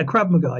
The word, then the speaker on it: Krav Maga: Yeah, Krav Maga.